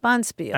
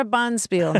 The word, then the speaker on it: bonspiel: Bonspiel. – Accent on the first syllable. – Yeah, we're getting together for a Bonspiel.